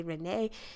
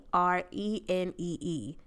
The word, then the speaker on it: Renee: I am at Tamara J Renee.